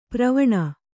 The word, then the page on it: ಪ್ರವಣ: pravaṇa